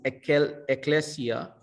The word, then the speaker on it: ekklesia: The Greek word for church is ekklesia. It's spelled E-K-K-L-E-S-I-A.